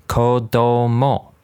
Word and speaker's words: kodomo: That would be kodomo. Kodomo. Kind of sounds like Komodo dragon, one of those lizard things.